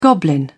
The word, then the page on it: goblin: British English